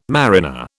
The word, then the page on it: mariner: British English